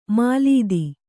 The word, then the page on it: ಮೆಲ್ಲಿದು: kannada